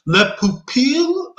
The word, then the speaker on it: Le Pupille: I heard that Le, Pup- Le Pupil, um, people have been saying it wrong because people have been saying it like in a French accent, and apparently it's an Italian accent that you're supposed to say it.